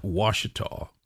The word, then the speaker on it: Ouachita: Okay, what is it now? – Ouachita. – Ouachita.